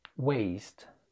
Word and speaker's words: waste: W-E-S-T. إيش معنى؟ لأن دي نطقها كده. West. West. سريعة كده. إنما الاتنين التانيين نفس النطق. نطقهم كده. Waste. Waste. بطيء شوية. يبقى عندي أول كلمتين نفس النطق. بطيء. Waste. والكلمة التالتة نطقها مختلف عنهم وهي سريعة شوية. West. West. فيبقى عندي البطيء Waste والسريع West. Waste. West.